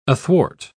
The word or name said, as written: athwart